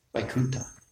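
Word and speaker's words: Vaikuntha (वैकुण्ठ): Some words we we pronounce the th like it's supposed to be in Sanskrit. Ta, Vaikuntha, and Thakur.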